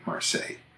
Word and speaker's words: Marseille: When referring to the city of Marseille in France, we say it pretty much the French way, Marseille. But the city in Illinois by that name is pronounced Marseilles.